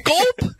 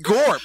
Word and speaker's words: gorp: Gulp? – I don't know what you're saying. – Gulp. – It's pronounced gorp. Just gulp. Say it. Gorp. – Gulp. – Gorp. Say it. Pronounce your R.